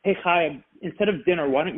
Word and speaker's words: Chaya: So when Chaya Hanan, Right, that's how she pronounces her name, C-H-A-Y-A, space C-H-A-N-I-N, Chaya Fanin.